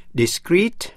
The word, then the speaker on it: discrete: Discreet, D-I-S-C-R-E-E-T là giữ kín. Và discreet, D-I-S-C-R-E-T-E là rời rạc. – Phân tách. Phát âm như nhau, discreet.